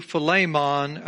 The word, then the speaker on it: Philemon: It's Greek. It's where we get our word Philemonion from. I'm just kidding. Just kidding. But it's a good way to remember how to pronounce it. It is Philemon.